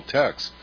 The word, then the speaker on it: Taix: It's still there, called Tex. – Oh, yeah, that's right. – It's spelled T-A-I-X, yeah. It's a French place.